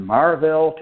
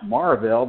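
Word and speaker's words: Maryville: And he didn't want to work in the field, so he opened a one-room schoolhouse in Marville, Tennessee. It's pronounced Marville, but it's M-A-R-Y-V-I-L-L-E. But if you live there, it's Marville.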